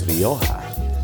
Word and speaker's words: Rioja: They keep correcting me because I actually be pronouncing the J, but when you speak in Spanish, the J is an H. So it's Rioja.